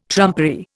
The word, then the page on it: trumpery: Indian